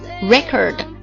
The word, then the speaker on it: record: 歌名 record 名词，读作 record，记录唱片经历的意思。动词 record，记录、录制、记载的意思。